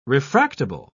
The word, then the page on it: refractable: British English